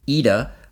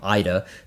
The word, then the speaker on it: Ida: Okay, this is a film by the director of Ida, or ID, Ida, depending on how you want to pronounce it.